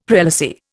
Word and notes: prelacy: Indian

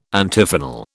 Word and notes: Antiphonal: British English

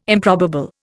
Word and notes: improbable: Indian

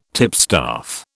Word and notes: tipstaff: British English

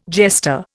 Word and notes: jester: Indian

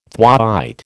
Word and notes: thwaite: American English

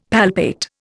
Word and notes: palpate: Indian

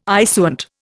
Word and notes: issuant: Indian

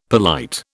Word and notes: polite: British English

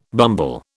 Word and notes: bumble: British English

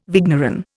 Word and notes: vigneron: Indian

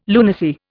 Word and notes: lunacy: Indian

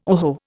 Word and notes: oho: Indian

